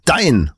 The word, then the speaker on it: DAIN: Ganz vorne ein D-A-I-N, Dein.